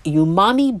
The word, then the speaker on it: umami: The first book is Umami Bomb, 75 Vegetarian Recipes That Explode with Flavor, 2019 by Raquel Pelzel. Umami, pronounced umami, is often referred to as the fifth taste because the richly satisfying sensation defies categories of sweet, sour, salty, or bitter.